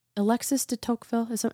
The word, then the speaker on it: Alexis de Tocqueville: Oh, I think it was maybe Dear Sister Christiana yesterday posted a quote from Alexis de Tocqueville. Am I saying that right? – Uh-huh. Yeah.